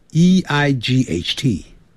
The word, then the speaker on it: eight: 要把上牙齿咬的下嘴唇发 v 的音 seven seven，八 e i g h t，那个好念 g h 不发音，念成 eight eight eight，九呢，因为是 n i n e，所以前面有个 n 就要念成 n。